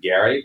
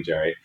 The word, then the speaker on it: Gerry: The sort of the word gerrymander comes from this famous political cartoon about a map drawn by Elbridge Gerry, whose name I am told was actually pronounced Gary, but that ship has sailed. Like, everybody calls it gerrymandering. So sorry to the descendants of Elbridge Gerry, but we're calling him Gerry.